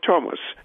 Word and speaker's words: Thomas: We still pronounce the name of the river Thames, even though it is spelled with a TH. And the same is true of Thomas.